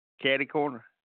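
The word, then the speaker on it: catty-corner: If I were to see it and didn't know the pronunciation of it, I would have said cater corner, but it's catty, and it's an adverb or an adjective.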